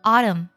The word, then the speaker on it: autumn: 还有最后一个单词，这个单词其实同学们还比较熟悉，就是秋天这个单词，我们读作 autumn。最后呢，两个字母，一个是 M，一个是 N，但这里 N 是不发音的，读作 autumn autumn autumn。